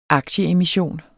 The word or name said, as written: aktieemission